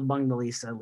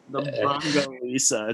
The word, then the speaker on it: Bundesliga: Now that your mispronunciation has gone into two weeks, I just allowed it to happen last week, but it's the Bundesliga. It's the funniest thing when you said it a couple times last week.